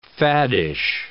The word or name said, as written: faddish